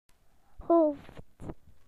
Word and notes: head: British English

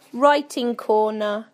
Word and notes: writing corner: British English

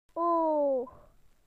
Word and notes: eye: British English

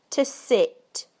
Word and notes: to sit: British English

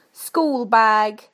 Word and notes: schoolbag: British English

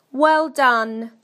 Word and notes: well done: British English

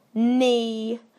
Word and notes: knee: British English

